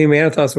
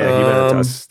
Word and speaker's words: Humanitas: Yeah, it's the Humanitas Award. – Yeah, Humanitas.